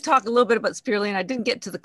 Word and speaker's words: spirulina: Earlier that you touched on spirulina.